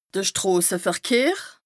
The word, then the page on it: de Stroseverkehr: Bas Rhin